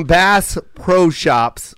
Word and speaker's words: Bass Pro Shops: Theo looks like he shops from Bass Pro Shops. Wait, did I say that right? – Yeah I read that right.